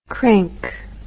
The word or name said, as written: crink